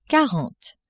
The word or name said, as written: quarante